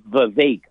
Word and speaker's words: Vivek: And he said Vivek, like bake.